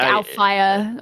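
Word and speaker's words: Alfajer: I think, you know, Alfair, is that how you pronounce it? Alfair? Is that right? – Yeah, it's like Alfire. – Alfire? Oh, okay.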